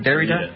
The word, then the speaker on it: Derrida: is that how you say it, Doug? Derrida? – Yeah.